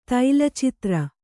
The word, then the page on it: ತೈಲಚಿತ್ರ: Kannada